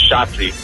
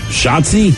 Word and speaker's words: Shotzi: We used to have a dog named Shotzi. – Shotzi? – Shotzi. – Chachi, as in like Joni and Chachi? – No, like rhymes with the dice game Yahtzee, but with a SH.